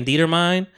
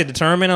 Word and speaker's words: determine: like when I was saying Dietermine and I heard somebody say determine, I was like, that's it. It's not Dietermine anymore.